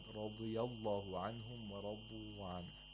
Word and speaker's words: radiallahu: And it should not be like an English R. Radiallahu is incorrect. It's not radi. – We need the tapping sound, as you said. – Radiallahu anhum.